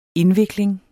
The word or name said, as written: indvikling